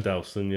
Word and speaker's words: Delson: Nelson, Delson? – Yeah, Delson, yeah.